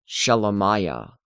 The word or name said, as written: Shelemiah